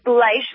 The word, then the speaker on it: Bleich: It's Bleich, long I, like German.